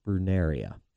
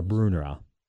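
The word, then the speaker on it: Brunnera: I love the Jack Frost Brunaria, or Brunera, yeah. – depending on how you want to pronounce it.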